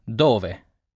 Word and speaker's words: dove: The first part, dove, pronounced dove, sounds a little like the word duvet.